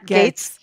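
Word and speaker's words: Gaetz: Gates.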